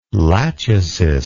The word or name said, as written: Lachesis